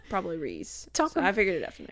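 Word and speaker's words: Rhys: Maas fans, like, flock to the group chat and be like, it's pronounced Reese.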